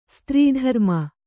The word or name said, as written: ಸ್ತ್ರೀಧರ್ಮ